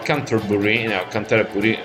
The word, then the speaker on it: Canterbury: David Allen, un chitarrista e un frontman australiano, che però si era trasferito a Canterbury, se lo vogliamo pronunciare all'italiana, in Inghilterra,